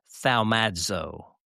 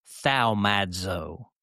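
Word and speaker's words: thaumazo (θαυμάζω): It's pronounced in Greek, thaumazo, thaumadzo.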